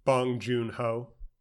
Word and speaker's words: Bong Joon-ho: How do you say his name? – Bong Joon-ho.